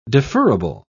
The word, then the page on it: deferrable: British English